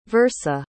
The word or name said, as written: versa